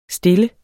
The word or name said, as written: stille